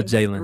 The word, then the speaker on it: Jalen: Look, y'all, you can find me on all platforms, Instagram, Twitter, Facebook at I am Jalen Jones. And my name is spelled J-A-L-E-N. Yep. It's about 735 ways to spell Jalen. – Right. – But it is J-A-L-E-N.